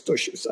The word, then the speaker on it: Tosches: I don't know how you pronounce his name. T-O-S-C-H-E-S, Toshis, I guess.